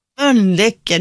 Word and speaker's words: Mölnlycke: Du uttalar Mölnlycke väldigt konstigt. Det är som med Mölndal, att ni utifrån kan vara... Säg Mölnlycke. – Mölnlycke. Du liksom uttalar för mycket L.